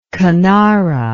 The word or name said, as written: kanara